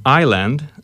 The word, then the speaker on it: island: And then there was another one, island, often mispronounced. Island, which is isla.